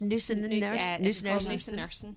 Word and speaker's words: Nusinersen: Nusinersen.